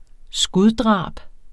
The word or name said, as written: skuddrab